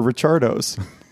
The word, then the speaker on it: Richardo's: And it is, I don't know if this is pronounced Ricardo's or Richardo's. I'm assuming it's Ricardo's. – I think so. – But Ricardo doesn't usually have an H in it, does it? – It's Richard O's.